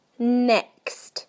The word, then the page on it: next: British English